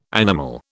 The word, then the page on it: animal: British English